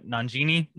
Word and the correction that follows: Nanjiani: Nanjiani. – Yeah.